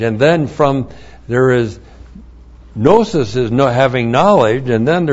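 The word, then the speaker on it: gnosis (γνῶσις): The noun, gnosis, and I know you call it gnosis, but no well-respecting Greek would ever say gnosis. G-N long O-S-I-S is gnosis, comes from gnosko.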